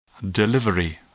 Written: delivery

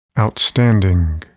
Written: outstanding

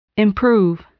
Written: improve

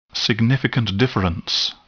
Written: significant difference

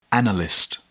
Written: analyst

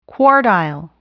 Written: quartile

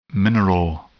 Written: mineral

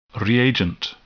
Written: reagent

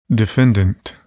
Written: defendant

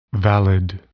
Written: valid

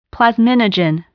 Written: plasminogen

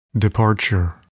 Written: departure

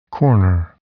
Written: corner

